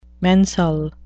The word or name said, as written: mensal